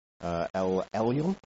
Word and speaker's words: El Elyon: So a couple of weeks ago, Martin introduced us to El Elyon. Have I pronounced that correctly? Nailed it.